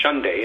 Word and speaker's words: Hyundai: And the reason they can fly such a big jet here is because we have Hyundai and, or Hyundai, as they pronounce it correctly over there, and Kia in our backyards.